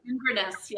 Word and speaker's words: Inverness: Inverness, is that how you say it? Inverness, yes. Inverness, a retreat in Inverness this September? – Not this month, but next, next September.